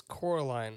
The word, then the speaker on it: Coraline: Is it Coraline or Caroline? – It's Coraline. – Coraline. – What's spelled Coraline? I thought he was saying Caroline, but it's spelled Coraline.